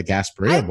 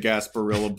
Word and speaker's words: Gasparilla: First of all, I like that you just pronounced it with the double L in Spanish. – Mm-hmm. Yeah, you pronounce it as if the people who made the Gasparilla Bowl spoke Spanish or respected it enough. – Wait, you turned it into a menu item. – It is. It is pronounced with the L.